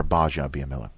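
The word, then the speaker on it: Gbaja-Biamila: By the way, that's it's a silent G. – It's a silent. – It's Akbar Baja Biamila.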